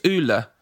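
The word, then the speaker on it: Ole: Do you know that his name is actually pronounced Ulla? I had no idea, Ulla.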